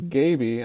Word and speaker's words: Gaby: I listened to the podcast, and I realized that I was pronouncing Gene Kelly's character's name as Gabby, but actually it was Gaby, and I guess that's probably short for Gabriel or something like that, but it was Gaby.